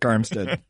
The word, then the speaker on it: Arik: Oh, and that's not how you say it, but yeah. – How do you say it? – I think it's Eric. – It's Eric Armstead. – Spell Eric. – Well, he spells it A-R-I-K, right? – That's correct.